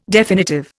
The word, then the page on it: definitive: Indian